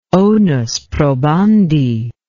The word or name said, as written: onus probandi